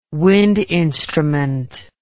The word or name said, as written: wind instrument